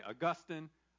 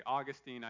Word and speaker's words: Augustine: Two ways that people pronounce his name. Some say Augustine, others say Augustine. I prefer Augustine, but either one is fine.